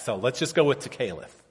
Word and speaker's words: tekelet: The word is spelled T-E-K-E-L-E-T, and it's pronounced tekeleth.